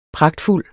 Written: pragtfuld

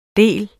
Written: del

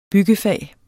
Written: Byggefag

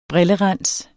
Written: brillerens

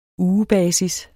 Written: ugebasis

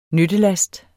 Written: nyttelast

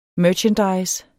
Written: merchandise

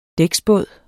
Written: dæksbåd

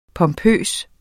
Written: pompøs

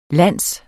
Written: land

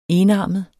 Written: enarmet

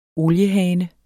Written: oliehane

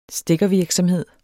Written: stikker

